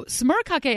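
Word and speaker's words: smørkake: So smörkake, as we discussed with Lindy, is the Norwegian pronunciation.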